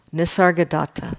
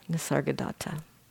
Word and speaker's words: Nisargadatta: Nisargadatta, thank you. Nisargadatta.